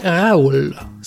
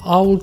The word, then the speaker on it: Raowl: C'est la BD Raoul. Ça s'écrit R-A-O-W-L. Raoul comme hibou, je pense.